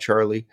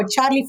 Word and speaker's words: Charlee: And we'll look forward to hearing more about Charlee as time goes on. – Well, it definitely rhymes with Lee.